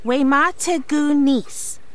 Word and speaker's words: Wemategunis: It's pronounced Wemategunis.